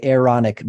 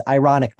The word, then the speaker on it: Aaronic: you would say the Aaronic blessing. I think I'm saying that right. There are two A's. And I always try to like not pronounce it as ironic blessing because that sounds ridiculous.